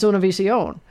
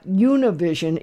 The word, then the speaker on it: Univision: No, it's Univision. It's not Univision, even though we might want to say that based on the way that it is spelled.